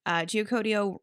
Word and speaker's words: Geocodio: deserts and our branding from the beginning was Geocodio rhymes with rodeo, and we had all of this Southwest style branding.